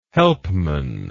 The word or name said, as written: Helpmann